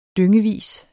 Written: dyngevis

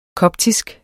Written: koptisk

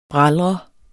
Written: bralre

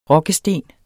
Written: rokkesten

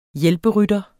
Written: hjælperytter

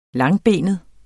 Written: langbenet

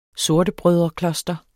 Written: sortebrødrekloster

